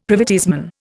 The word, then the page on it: privateersman: Indian